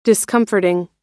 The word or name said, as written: discomforting